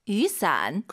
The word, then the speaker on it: 雨伞: 우산 두 글자로 雨伞. – 그 비 오다 할 때 YU 발음이요. 예, 위 아니고 유.